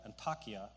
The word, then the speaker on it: Antakya: That's probably a little bit on the high side, I think, because the population today in what is now Antioch, they call it Antakya, is how it's pronounced now, there is probably only about 190,000 people.